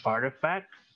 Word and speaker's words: RTFKT: In December, they bought the virtual sneaker company RTFKT, which is pronounced Artifact, for an undisclosed amount.